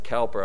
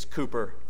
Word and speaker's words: Cowper: Sometimes we pronounce it Cowper. I was corrected recently. I think it's Cooper.